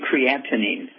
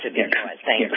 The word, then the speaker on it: creatinine: Okay, it's actually creatinine, is the pronunciation? – Yes, creatinine.